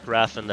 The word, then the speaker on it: Raffan: It's Mark Raffan. That's spelled R-A-F-F-A-N.